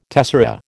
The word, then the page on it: tessera: American English